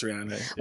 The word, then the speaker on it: Rihanna: That and her name is pronounced Rihanna.